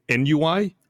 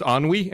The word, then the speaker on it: ennui: And our English teacher was trying to teach us or introduce the concept of ennui and introduce it to the class, except she kept pronouncing it NUI. – No. – Okay. – And, Frank, of course, raises his hand. It's like, I believe it's pronounced ennui. And she's like, no, it's NUI.